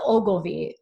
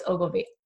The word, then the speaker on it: Ogilvy: So David Ogilvy, I always say his name wrong. No, it's Ogilvy. You guys, I should have had more coffee or rescheduled.